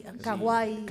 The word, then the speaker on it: kawaii: kuki, kawaii.